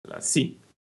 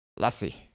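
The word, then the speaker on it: LaCie: Także, no cóż, ale słuchajcie, e, Lacey, czy jak to się wymawia? Przepraszam. Lassie. Lassie? Lassie.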